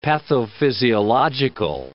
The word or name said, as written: pathophysiological